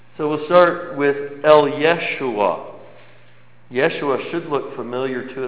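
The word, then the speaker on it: Yeshua (ישוע): But Yeshua is the more, a little closer to the way the Hebrews, the Israeli people would pronounce it. The Israelites would have pronounced it, Yeshua.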